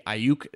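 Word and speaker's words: Aiyuk: When you look at wide receivers at the end of the first round, I think the ASU guy is really, Ayuk is how you say his name.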